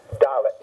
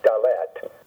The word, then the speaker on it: Dalet: In the alphabet, when you have a two-syllable word, the accent's always going to fall on the first syllable. So it's going to be Aleph, Bet, Dalet, not Dalet. The Aleph, Bet, Gimel, Dalet, and then Hey, and sometimes that's written as H-E-Y, sometimes it's just H-E, but the idea is the H sound.